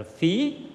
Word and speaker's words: phí: Chữ phất hay gọi là đọc là phí, tức là nước sôi.